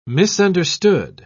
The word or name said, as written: misunderstood